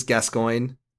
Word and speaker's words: Gascoigne: People will argue about the pronunciation. I believe it is Gascoigne. How do you say it? – Yeah, I'll co-sign that. – What do you say? What do you say though? – No, I say Gascoigne.